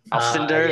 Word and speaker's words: Alcindor: Alcindor, is that how you say it? – Yes.